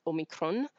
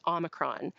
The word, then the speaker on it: Omicron: But that's the UK Canadian sort of pronunciation, is trying to be close to the Greek. The American pronunciation is more like a short O, so it's Omicron. And that comes more from looking at other words that have O-M at the beginning, where you wouldn't say it, I'm eating an omelet. You say I'm eating an omelet. So they're both considered correct pronunciations.